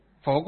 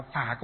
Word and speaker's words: phổ: Miền Bắc chúng ta đọc là phả cúng dường chân ngôn, miền Nam đọc là phổ cúng dường chân ngôn. Chữ phổ và chữ phả, âm phát âm có khác nhau nhưng nghĩa thì đồng một nghĩa. Phổ và phả thì là cách phát âm giữa miền Nam miền Bắc mà thôi.